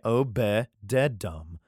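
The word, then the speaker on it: Obed-edom: and Abededom and Jael the porter. I'd hope Abededom is in there. I'm saying that right. Obededom.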